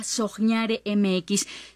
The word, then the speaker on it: soñare: Síganos en Instagram y TikTok como @soñaremx. Se pronuncia soñare, se escribe con qué, soñaremx.